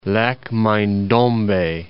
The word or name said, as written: Mai-Ndombe, Lac